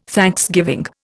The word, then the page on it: thanksgiving: Indian